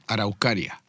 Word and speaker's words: Araucaria: Araucaria.